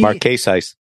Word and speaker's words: Marquesas: We're going to end up with this story coming out of our Marquesas Key. – Marquesas. – We discussed this during the break, and you said I pronounced it exactly the way you told me.